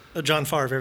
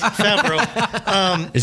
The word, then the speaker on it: Favreau: He says John Favre every time. But it's Favreau.